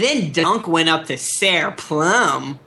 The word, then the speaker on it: Ser: No, so like, I guess it's Sir, which is S-E-R, but like he pronounces it Sair.